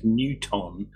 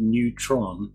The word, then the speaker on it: Neuton: It's very irritating that the name of the company presumably is Newton, and it's extremely hard to look at that word and not pronounce it Neutron. – Because it looks like Neutron.